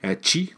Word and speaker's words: Qi: chi, euh, donc QI, ça se prononce Chi, ça l'air.